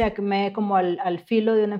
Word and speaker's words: ACME: Bueno, ACME básicamente, bueno, el significado de ACME con acento en la E,